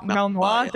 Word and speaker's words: Malinois: A Belgian Malinois. Is that how you say it? Malinois. Thank you, Chris.